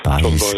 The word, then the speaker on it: Baez: Joan Baez oder Baez? – Baez, gell, ja. – Uso. – Latinisch ausgesprochen. Ja.